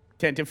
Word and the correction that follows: Tantive: Pronounced tantive.